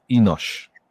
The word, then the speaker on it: Enos: And he called his name Enos. It's also, it can also be pronounced Enosh.